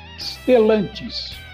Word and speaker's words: Stellantis: que significa iluminar com estrelas o nome é escrito com S mudo e o resto como se pronuncia, Stellantis, porém com dois L's.